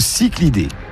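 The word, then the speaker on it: cichlidé: Alors, le cyclidé, ça s'écrit C-I-C-H-L-I-D, donc le cyclidé.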